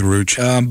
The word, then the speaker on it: Ruch: Yeah, and I don't know how to pronounce the young lady's name, R-U-C-H, but... – Is it Rooch or Rooch? – Yeah, go with Rooch.